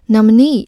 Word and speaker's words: nominee: 最后这个单词 nominee，它的重音在最后一个 ne 的前面，读作 nominee。